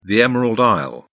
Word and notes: emerald isle: British English

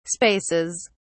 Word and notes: spaces: British English

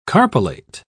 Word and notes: carpellate: British English

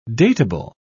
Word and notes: datable: British English

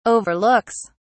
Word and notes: overlooks: British English

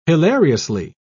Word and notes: hilariously: British English

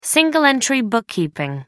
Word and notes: single-entry bookkeeping: American English

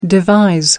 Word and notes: devise: American English

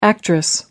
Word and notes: actress: American English

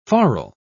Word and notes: forrel: British English

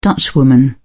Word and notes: dutchwoman: British English

